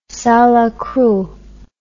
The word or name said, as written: salacrou